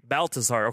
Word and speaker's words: Baltazar: It's Baltazar.